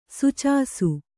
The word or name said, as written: ಸುಚಾಸು